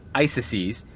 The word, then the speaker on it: ISIS: As many of you know, the Islamic State in Iraq and Syria, pronounced ISIS, has reportedly raised, quote, hundreds of millions of dollars, end quote.